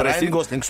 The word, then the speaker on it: Райан Гослинг: Надеюсь, я правильно произнес по ударению его имя, Да, но он меня да, Райан Гослинг, все хорошо.